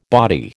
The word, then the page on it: body: American English